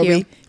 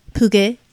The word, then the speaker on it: Pugh: I probably should have Googled how to pronounce this young lady's name properly before Pugh. Pugh, right? – Yeah. Yeah. – Pugue.